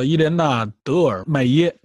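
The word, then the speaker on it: Hélène Delmaire: 对，叫呃法语发音叫做 Elena de la Mer，应该是这么一个发音。 – 对，叫伊莲娜· 德尔麦耶，翻译过来德尔麦耶啊，就是你说的这个发音。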